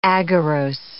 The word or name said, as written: agarose